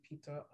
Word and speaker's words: Pita: So me, my personal is at Kofi Peter. The Kofi is my my African name, and Peter is spelled P-I-T-A because in college all my friends called me Peter, but I don't know why.